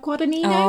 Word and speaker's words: Guadagnino: Guadagnino.